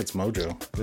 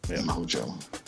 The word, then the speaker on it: Mojo: In one of the X-Men annuals, they were fighting, I don't actually know how you pronounce it, Mojo or Majo? – I think it's Mojo, is it? – Yeah. Mojo.